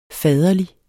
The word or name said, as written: faderlig